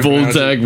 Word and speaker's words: Wojtek: Woljek, Woljek, and Woljek. – It's spelled W-O-J-T-E-K. But apparently it's pronounced V-O-Y-T-E-K.